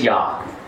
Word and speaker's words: Yah: we have 68 references in the Tanakh showing the beginning of the name is Yah. You know, so we know that. We know it's Yah.